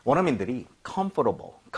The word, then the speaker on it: comfortable: 근데 이거 발음할 때 있잖아요. 원어민들이 comfortable. comfortable 이렇게 부드럽게 하는 사람도 있는데요. – 네. – 오히려 이 단어는요. 이런 발음을 들을 확률이 높아요. 뭐냐면 comfortable. 뭐냐면 여러분들 중간에 OR 보이죠? – 네. – 이거를 발음을 거의 안 하는 사람도 엄청 많습니다.